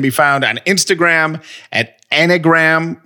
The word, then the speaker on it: Enneagram: Say it right. – Enneagram test. There we go.